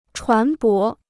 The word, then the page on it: 船舶: chuán bó